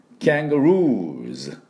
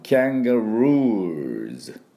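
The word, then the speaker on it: kangaroos: ですから、今日は複数形でいこうと思いますが、これね、最初の音がミャーのとキャーンなんですね。キャングルーズ、ルーのところになんとアクセントがあるんです。だからカンガルーじゃなくてキャンガルーズ、ね、ルーって引っ張って、そこにウー、ね、そこにアクセントで、最後はズ、 Z の音になります。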